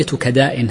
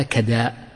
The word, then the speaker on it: كداء: وثنية كداء هكذا تنطق بفتح الكاف والدال لأن بعد حرف مد وتكون ممدودة مهموزة، فدل ذلك على أنها تنطق هكذا كداء، خلافا لمن يعني عابه صاحب القاموس وغيره فصحف في ضبط هذه الكلمة.